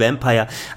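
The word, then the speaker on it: Vampyr: Vampir, Vampire mit einem Y, wie soll das gehen? Und ich habe tatsächlich beide Sprachweisen gefunden. Die Entwickler haben sowohl Vampire als auch Vampir genannt, je nachdem, wer der Gegenüber, der Interviewer, dann gewesen ist.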